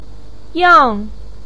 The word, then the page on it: yang: 4